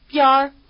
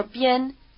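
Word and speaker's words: 边儿: The bound form biar or bien, as it's pronounced outside of Peking,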